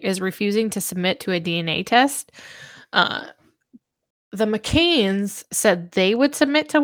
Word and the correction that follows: McCann: McCann. Sorry. – It's okay. – It is McCann. My bad. I said it wrong the whole time.